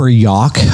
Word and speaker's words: Yough: And we happened, it was on the upper Yauk, I guess is how they pronounce it. It's Y-O-U-G-H. Yeah, the Y-O-U-G-H, Yauk.